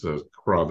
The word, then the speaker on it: Cras: So that's by this Kra that you're going to be hearing. But how do you spell that? – C-R-A-S. – Yes, Jean Kra.